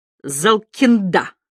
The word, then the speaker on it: Залкинда: Первые четыре фамилии он произнес скороговоркой, а последнюю — с ударением на последнем слоге, как будто колоду опускал. Залкинда.